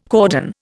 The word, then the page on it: cordon: Indian